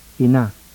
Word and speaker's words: ina: Now, words having an acute stress on the last syllable, the word for mother. – Ina.